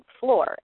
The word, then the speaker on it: Flor: It's called Floor, F-L-O-R, sort of like the first syllable of uh-huh. – Florida.